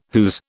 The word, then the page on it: whose: British English